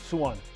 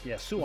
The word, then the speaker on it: Suwon: Is that how you say it? – Suwon. – Suwon. – Yes, Suwon.